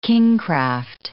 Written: kingcraft

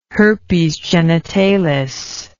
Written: herpes genitalis

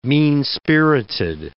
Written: mean-spirited

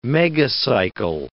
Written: megacycle

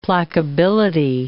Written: placability

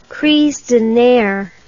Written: crise de nerfs

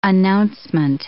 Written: announcement

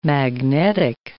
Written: magnetic

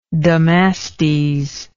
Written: damastes

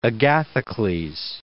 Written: Agathocles

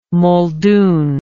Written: muldoon

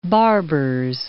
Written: Barbers Point

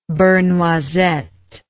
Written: beurre noisette

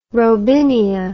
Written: robinia

